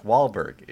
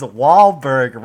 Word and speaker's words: Wahlberg: Well, the problem is Wahlberg rhymes with Smallberg.